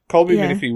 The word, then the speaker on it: Colby Minifee: Like I said, I actually really liked Colby Minifully. I don't know how you say her name. Minifee? Colby Minifee was Robin.